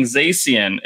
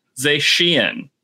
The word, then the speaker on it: Zacian: And I found out on that day that what we've been calling Zacian is actually pronounced Zacian. That CI is apparently doing some SHs work there.